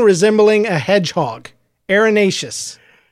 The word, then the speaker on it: erinaceous: Hey, Google, how do you pronounce E-R-I-N-A-C-E-O-U-S? – That's pronounced arenaceous.